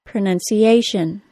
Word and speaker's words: pronunciation: In the word pronunciation, that means that the nun syllable receives the secondary stress.